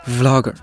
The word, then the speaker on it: vlogger: 但对于我们今天的嘉宾 Vlogger 景月来说，这只叫小香的布偶是他的养子。也是他 vlog 作品里面非常重要的角色。有没有注意到我刚刚那个英文单词的发音？我再来一遍啊，vlogger，注意，一定要上排的牙齿轻轻地咬到下嘴唇发音。 v v vlogger。